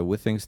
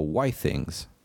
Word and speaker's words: Withings: a właśnie ostatnio słyszałem, że to się nie wymawia Withings, tylko Why Things. Why Things? Tak, od Wi-Fi i Things.